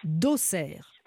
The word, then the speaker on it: Auxerre: D'Auxerre. Oui. Et c'est bien comme ça qu'on prononce.